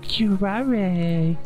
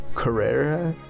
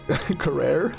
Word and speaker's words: curare: Is that how you pronounce it? – Curare. – Carrera? – Curare.